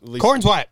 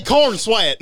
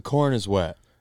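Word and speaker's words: Corenswet: So I looked up how to say his name. – Corn's wet. – Hey, corn sweat. How you doing, buddy? – No, it's the corn is wet.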